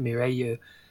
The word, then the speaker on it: Mireille: They pronounce it Mireille because of the... – Because Japanese consonants.